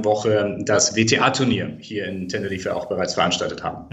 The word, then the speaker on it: Tenerife: Teneriffa, Tenerife, wie es dann im Spanischen ausgesprochen wird.